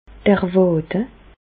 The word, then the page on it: d'r Wàde: Bas-Rhin; Reichshoffen